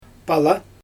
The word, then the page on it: pala: Xyzyl